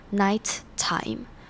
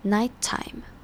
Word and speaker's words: nighttime: 这边的 nighttime 是一个字，而这两个音节，第一个音节的结尾也是 t，第二个音节的开头是 t，那这两个 t 只要念一次就好了，不用念成 nighttime，而是念成 nighttime，两个 t 只要念一次，这样听起来会比较顺。